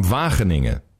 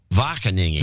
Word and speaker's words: Wageningen: From Wageningen. – Wageningen.